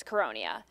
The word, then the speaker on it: Caronia: That's how my last name's really supposed to be pronounced, but for whatever reason, my family doesn't pronounce it that way. But the Italian pronunciation, or the Americanized version of the Italian pronunciation, is Caronia.